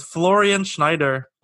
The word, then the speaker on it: Florian Schneider: But Florian Schneider passed away.